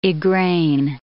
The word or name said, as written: Igraine